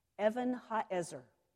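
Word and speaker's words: Evan ha'ezer (אבן העזר): The word Ebenezer is actually two Hebrew words pronounced together. Evan ha'ezer.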